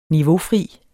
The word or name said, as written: niveaufri